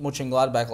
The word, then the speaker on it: Mönchengladbach: They actually beat Mönchengladbach last week, which is a huge win for them.